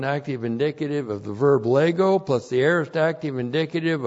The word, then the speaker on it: lego: And then we have lego. L-E-G, long O, lego.